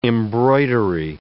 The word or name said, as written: embroidery